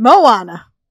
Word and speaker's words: Moana: That is from Moana.